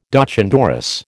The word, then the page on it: doch an dorris: American English